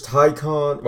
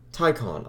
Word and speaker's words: Taycan: We're going to be talking about the news, Taycan, well, Porsche Taycan stuff. It sounds so wrong. Taycan? Taycan. I know I'm saying it right, but Taycan, that's such a weird name.